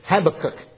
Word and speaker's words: Habakkuk: And old Habakkuk said, Habakkuk is how you say it.